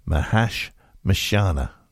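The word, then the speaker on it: Mahashmashana: It's Mahashamashana. oh dear. Mahash Mashana. There we go.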